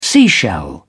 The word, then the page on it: seashell: British English